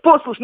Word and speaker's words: послушник: Ну вот ни один нормальный человек, извините за выражение, не произносит это слово правильно с ударением на первый слог. Вот, послушник, ну никто так не говорит, и послушница. Все говорят послушник и послушница.